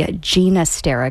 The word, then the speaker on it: Ginastera: And yes, before someone goes to their email and decides to send an email correcting me on the pronunciation, the composer did prefer the pronunciation with the soft G, Ginastera, because that was the Catalan version.